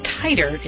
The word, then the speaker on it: titer: And it's titer. It's spelled T-I-T-E-R.